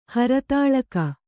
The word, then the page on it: ಹರತಾಳಕ: haratāḷaka